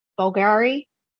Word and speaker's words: Bulgari: It's, how do you say it? Bulgari? – Yes. Yes.